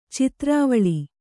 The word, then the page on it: ಚಿತ್ರಾವಳಿ: Kannada